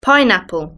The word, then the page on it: pineapple: British English